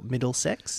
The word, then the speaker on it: MDLSX: And one of the projects as part of BODY that I'm really excited about is a performance called MDLSX or Middlesex. – I was trying to figure out, oh my God, that's how you pronounce it.